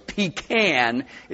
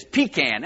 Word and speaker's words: pecan: That's the correct way to say it, by the way. Pecan is the correct. I'm from Georgia, okay, in the latter part of my life. The pecan state, it is pecan.